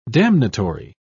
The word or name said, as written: damnatory